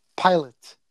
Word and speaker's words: Pilate: Pilate. – Pilatus.